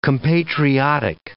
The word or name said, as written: compatriotic